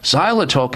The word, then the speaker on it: xylitol: The best way to make sure you keep your mouth clean is by incorporating something called xylitol into your life. Xylitol. If you don't know about what xylitol is, you should. Xylitol is spelled with an X. X-Y-L-I-T-O-L. Xylitol.